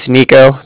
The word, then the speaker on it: Niko: All right, like I said, my name's Nico. That's spelled N-I-K-O. – Okay. – And I'm calling from Cardiff, and that's spelled C-A-R-D-I-F-F, as in Frank, Frank.